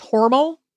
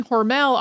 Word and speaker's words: Hormel: Now, their last name was originally pronounced Hormel to rhyme with normal, and it's not totally clear when Hormel became Hormel. Because we've both been saying Hormel all our lives, and for the sake of consistency, we're just going to go with Hormel.